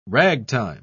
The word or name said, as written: ragtime